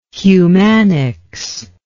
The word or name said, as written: humanics